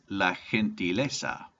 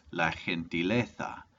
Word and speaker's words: gentileza: Number seven is la gentileza, or in Spain this would be pronounced la gentileza.